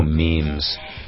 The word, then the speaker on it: meme: 就这种 social media memes. – Oh, memes. – 这个the spelling, 这个词的拼法 is it M-E-M-E, right? – 对 M-E-M-E. – Meme. – But it's not pronounced meme. – No, it's meme.